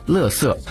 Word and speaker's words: 垃圾: 另外垃圾这个词呢也很常见，在我们内地读作垃圾，在台湾读作垃圾。